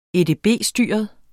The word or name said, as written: edb-styret